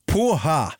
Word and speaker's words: porra: Porra uttalas poha och betyder fritt översatt sperma ungefär.